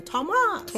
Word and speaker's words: Thomas: It's Thomas. You stress the beginning, Thomas. You have to sing it, Thomas.